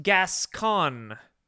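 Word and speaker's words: Gascón: It's pronounced Gascon.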